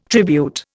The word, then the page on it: tribute: Indian